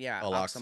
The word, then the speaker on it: Al-Aqsa: This is so religious that the site of the third temple that they want to rebuild the temple is where the Al-Aqs Is that how you pronounce it? – Al-Aqsa Mosque. – Yeah, Al-Aqsa from the Muslim faith.